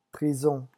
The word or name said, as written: prison